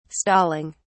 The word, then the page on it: stalling: British English